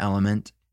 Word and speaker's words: LMNT: Have you heard about Element? It's spelled L-M-N-T.